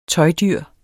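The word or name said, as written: tøjdyr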